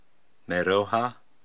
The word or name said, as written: Meyroha